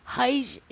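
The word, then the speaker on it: hayır: Hayj is spelled H-A-Y-I-R, and it's pronounced hayj, and it means the word no in Turkish.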